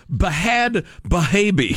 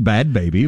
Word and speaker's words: Bhad Bhabie: Herself Bahad Bahabi. – I think it's pronounced Bad Baby, but it's spelled...